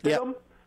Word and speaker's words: Stidham: Is it Stidham? – Yep.